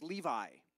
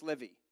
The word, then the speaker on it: Levi: One of his sons is what's called in English, or, or translated normally in English, is Levi, like the jeans. But it's really in Hebrew, it's Levi. Levi. Levi. The accent is on the on the end. Levi. Not Levi or Levi.